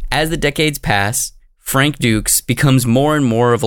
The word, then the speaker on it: Dux: Yeah, it looks like our boy Frank Dukes, which makes sense, but Dukes is how it's pronounced, not Ducks, even though I've been saying Ducks, because it's spelled D-U-X, Ducks.